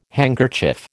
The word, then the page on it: handkerchief: American English